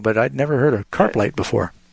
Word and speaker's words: Carp Lake: Talking next with Ed, who's calling from Carplight, Michigan. Am I saying that right? Ed, you're on the air with Bible Answers Live. – Yeah. Carp Lake is a fish. – Oh, okay. – The fish, carp.